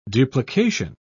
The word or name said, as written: duplication